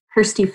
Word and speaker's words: Kjersti: Yes, um, my Instagram is at Kjersti Physiotherapist, and my name is a bit of an interesting spelling.